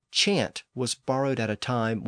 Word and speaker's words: chant: Modern French still has that word chant, pronounced today as chant. It's still spelled C-H-A-N-T in French, but it doesn't have the same meaning as the English word.